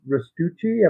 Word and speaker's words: Restucci: Tonight we are going to be with Rich Restucci.